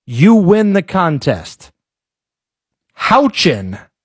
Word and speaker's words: Houchin: Houchin, as in Indiana's Aaron Houchin. – Do I need to use... Can you use it in a sentence or? – Aaron Houchin is how you pronounce this woman's name.